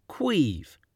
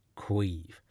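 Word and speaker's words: Caimh: namely, how do you say my name? I've just said it, but I'll happily do so again. Quive. It's Quive. Yes, I know, Gaelic is a beautiful yet ridiculous language.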